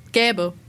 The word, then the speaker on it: gäbe: Nee, ich meine schon Gäbe, aber ich habe es gerade eher mit E gesagt, da hast du recht.